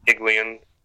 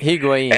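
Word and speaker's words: Higuaín: Columbus has Kai Kamara with 24 goals, Ethan Finley with 13, Federico Higuain. Yeah, I know I said that wrong. Sorry. 9. – Higuain.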